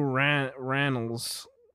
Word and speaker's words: Rannells: I don't know if I'm saying his name right, but I love that dude. – Andrew Reynolds? – Andrew Rannells. – Rannells, yeah.